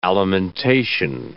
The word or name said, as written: alimentation